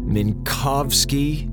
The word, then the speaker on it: Minkowski: Minkowski, Minkowski, Minkowski.